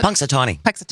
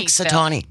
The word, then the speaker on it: Punxsutawney: How do you pronounce that? – Punxsutawney. – Punxsutawney, yeah.